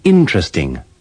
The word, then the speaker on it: interesting: Don't pronounce the second E in interest, interested, and interesting.